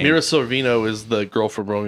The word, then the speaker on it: Mira Sorvino: Mira Sorvino.